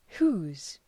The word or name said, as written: whose